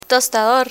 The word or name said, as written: tostador